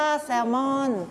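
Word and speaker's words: salmon: แซลมอนแต่ภาษาอังกฤษอ่านว่าแซลมอนแซลมอนไม่ออกเสียง L นะ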